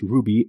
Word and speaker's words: RWBY: Now the name of Ruby, it's actually spelled R-W-B-Y, pronounced Ruby, and it's basically the first letters of the names of the four main characters.